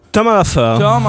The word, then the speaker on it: Tom à la ferme: Tom à la ferme.